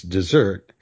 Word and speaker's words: dessert: People on menus are constantly spelling it D-E-S-E-R-T. And I think it's partly a problem because dessert is one of the few words in English with a double S that's pronounced to sound like a Z.